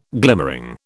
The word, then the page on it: glimmering: British English